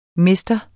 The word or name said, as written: mr.